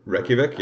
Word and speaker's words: Reykjavik: pronounce this T Re- Reykjavik. – Reykjavik, yeah.